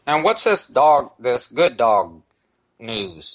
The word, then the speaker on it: Guddawg: And so this man has created, it's called Good Dog, and it's spelled G-U-D-D-A-W-G.